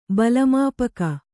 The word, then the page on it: ಬಲಮಾಪಕ: bala māpaka